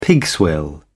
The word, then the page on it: pigswill: British English